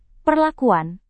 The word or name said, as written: perlakuan